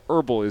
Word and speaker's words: Herbel: Everyone from that point on, it's Elijah Herbal is your name, and I totally have disrespected my ancestors. I've done it wrong the entire time. Everyone mispronounced my name, and I can't even be mad about it because it's my own fault.